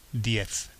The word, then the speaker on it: diez: So, that was, if you notice, the zero was pronounced cero, okay? And ten was diez, okay? Because that's typically the peninsular pronunciation in Spain.